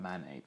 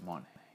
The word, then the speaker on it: Monet: And for some reason I keep mispronouncing Monet. Monet, that guy, the French dude. Not Manet, but Monet. A-N-O. Different people.